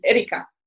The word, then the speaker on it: Erika: That in Medellin that had a very prominent figure, you know, character named Erika. I ended up inheriting that that name because of that novela. And it's funny because when I was growing up, my name is spelled with a K, E-R-I-K-A.